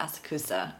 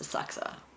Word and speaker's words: Asakusa: And I was like, oh, it's not Asakusa, it's Asakusa.